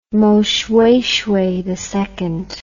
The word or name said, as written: Moshoeshoe II